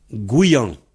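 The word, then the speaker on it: gouiañv: Et ça donne Guyan.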